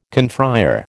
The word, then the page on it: confrère: American English